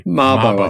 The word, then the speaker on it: Mabo: And his great uncle, Eddie Mabo, I don't know if I'm saying that right. – Mabo, I think, yeah.